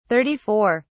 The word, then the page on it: 34: British English